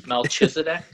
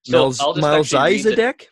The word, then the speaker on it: Melchizedek: How do you say that? – Melchizedek? – Yeah. Melchizedek. – Melchizedek. I like it.